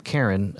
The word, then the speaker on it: Caron: The name Karen, which is spelled C-A-R-O-N, actually comes from Dick and Catherine.